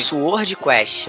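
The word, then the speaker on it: Swordquest: o Sword Quest. – Né, é, eu falei, eu falei, então eu falei certo, é Sword Quest.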